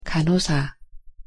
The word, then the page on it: 간호사: Korean